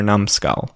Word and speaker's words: numbskull: You're a numb skull. N-U-M-B. So there's a silent B in there after numb, and skull is S-K-U-L-L. And again, it's a one word. N-U-M-B, S-K-U-L-L, numbskull.